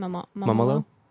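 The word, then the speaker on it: Mumolo: How do you say her last name? – Mamolo? Yeah.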